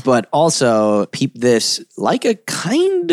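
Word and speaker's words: Laika: There is now a, am I saying it right, Leica? – Yeah.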